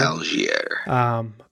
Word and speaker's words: Allgeier: This Atlanta dude that I never pronounce his name right, Tyler Allgeier. Yeah, Allgeier, whatever. – Allgeier.